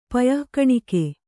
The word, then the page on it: ಪಯಃಕಣಿಕೆ: Kannada